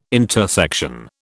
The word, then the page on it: intersection: British English